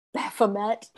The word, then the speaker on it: Baphomet: One particular treasure that has been a point of interest is a gold, glass, or wooden idol of Baphomet, which has also been pronounced Baphomet in the United States, because we just kind of see a thing and then pronounce everything in the United States.